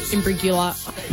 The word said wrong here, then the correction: Imbruglia: Natalie Imbruglia. Put it down. I'll give you that. – Soft G. – Soft G. – Imbruglia.